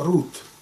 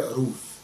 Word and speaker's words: Рут: что правильно это Рут, не Руф. Руф это сложившееся произношение через греческий перевод. Через греческий перевод Торы, Танаха в данном случае. А мы говорим Рут. Рут – это имя происходит от корня Раве, где В произносится как У. Вав произносится как У, иногда как В.